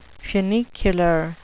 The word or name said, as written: funicular